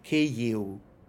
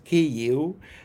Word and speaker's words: Kyiv: So Ukrainians go in the opposite direction, and instead of devoicing it, they vocalize it. And so V becomes a short U. So Ukrainians say Kyiv. So it's a lot of information to pronounce just one two-syllable word. But if you practice it, knowing all this, Kyiv, it becomes very, very easy, Kyiv.